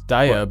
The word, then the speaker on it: Daya: It was Daya by the looks of it. – How do you spell it? – D-A-Y-A. – Daya. – Do you reckon? – It's like Maya. – Might have an I in it. – Maya's though. Sort of like M-A-Y-A, isn't it? – Is it?